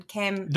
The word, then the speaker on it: Canberra: How do I say it? – Canberra. Properly? It is Canberra.